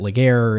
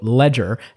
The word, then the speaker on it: Legere: So there were lots of people, I'm sure, have been wondering sort of how do you pronounce the guy's name? Is it Ligier or is it Ledger? He had a tweet a while ago that is, I know there's been some questions about it. It's pronounced, quote, Ledger, as in AT&T is about to jump off the ledge-er.